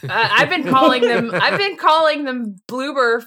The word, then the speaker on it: Bloober: And then I click a timestamp, and the first fucking thing I see in the timestamp is the Bloober logo! – Beautiful. Oh, man. – We kept mispronouncing the name earlier, calling them everything but what they actually were. Just complete disrespect. – Boober, booger.